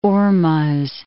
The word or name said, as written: Ormuz